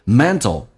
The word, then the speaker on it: mental: 慢慢的被浊化，有点近似于 S，比较短促，很轻，叫做 means， you see， managing 管理 managing your mental energy， mental T 跟 L 在一起，在美式发音趋向于发 D 跟 L，叫 mental，有点像 D 跟 L，有点像 T 跟 L，它属于这种比较含糊不清的，叫做 mental energy。